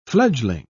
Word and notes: fledgeling: British English

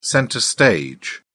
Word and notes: centre stage: British English

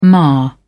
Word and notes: mar: British English

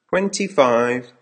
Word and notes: twenty five: American English

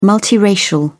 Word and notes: multiracial: British English

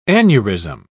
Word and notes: aneurism: British English

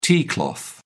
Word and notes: tea cloth: British English

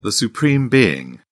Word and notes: supreme being: British English

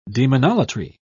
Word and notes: demonolatry: British English

